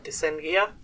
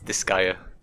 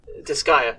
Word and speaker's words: Disgaea: I know what it is, but I'm going to butcher the pronunciation. It's something like Descendia? – It's Disgaea. – Disgaea. – But yeah, Disgaea.